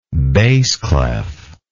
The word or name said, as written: bass clef